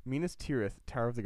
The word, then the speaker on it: Minas Tirith: Minas Arnor was renamed Minas Tirithil. Tower. Is that, I said that wrong? – Minas Tirith.